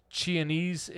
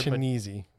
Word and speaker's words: Chianese: Dominic Chianese is Mr. Chianese. – Thank you. Yeah, I shouldn't have pronounced it that way. I practiced that and failed epically.